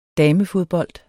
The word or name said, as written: damefodbold